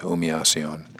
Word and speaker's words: humillación: So you're not really pronouncing the H or the L's, really. So humillación. Appears the H is silent and the L's sound more like a Y.